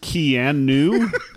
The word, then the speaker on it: Keanu: But it's pronounced Keanu.